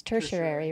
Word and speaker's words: tertiary: And then, please pronounce this word for me because I'm going to screw it up. I do it every time. What's that one? It's tertiary, right? – Okay. Yeah.